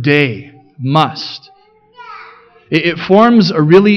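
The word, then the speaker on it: day (δεῖ): Now the Greek word that gets translated must there, it's in your insert, it's the little word, it's pronounced day, just like Sunday.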